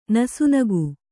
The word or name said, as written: ನಸುನಗೆ